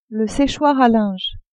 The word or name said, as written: séchoir à linge